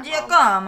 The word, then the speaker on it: Giacomo: I'm sorry if I'm pronouncing it Giacomo. – Wrong. Giacomo. If you just say it in Italian accent, it's going to sound good.